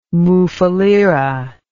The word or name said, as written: mufulira